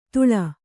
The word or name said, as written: ಧೂಳ್